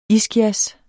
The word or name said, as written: iskias